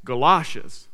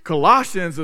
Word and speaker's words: Colossians: And one of the kids in there corrected me and said that the proper way to pronounce Colossians was Galoshes. I said, no, that's what you wear when it rains outside. Colossians is what we find in the Word of God.